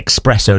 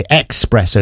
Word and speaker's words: espresso: A lot of people say espresso, don't they? Espresso. It's not espresso. Espresso.